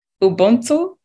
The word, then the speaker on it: Ubuntu: And I think that's Ubuntu. Is that how you say it? – Yes. Yes.